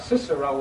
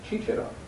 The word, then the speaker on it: Cicero: And in ecclesiastical Latin, Cicero would be Cicero. – Yeah? – Cicero. But not in the classical language of Virgil or Cicero or Seneca. Then it would be Cicero. K, it's pronounced. See? Cicero, K in the Germans took the classical pronunciation, and C-A-E, Caesar, or Cesare, to them became what? – Kaiser.